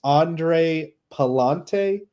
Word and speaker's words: Pallante: But yeah, so Richard Fitz is going against Andre Pallante. I'm pretty sure I have that right. – That's how I've always heard it pronounced.